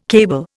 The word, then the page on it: cable: Indian